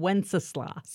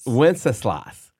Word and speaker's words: Wenceslas: Old King, how do you pronounce it? – Good King, not Old King, Good King Wenceslas. – Wenceslas. – Yeah, Wenceslas.